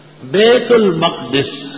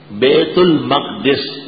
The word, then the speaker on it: بیت المقدس: بیت المقدس، بیت المقدس اور بیت المقدس دونوں لغت معروف ہیں، صحاح جوہری میں ہے. بیت المقدس اور بیت المقدس دونوں تلفظ صحیح.